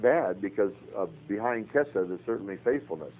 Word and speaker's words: chesed: Let's take a beautiful Hebrew word like chesed, which is spelled C-H-E-S-E-D.